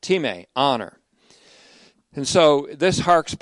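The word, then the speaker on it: time (τιμή): The transliteration of that looks like our word time, but it's really T-I-M-A-T-A-E, would be pronounced time in the Greek.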